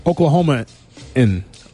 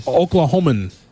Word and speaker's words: Oklahoman: Iowan, Oklahoma-in. – Oklahoma-in. – Oklahoman.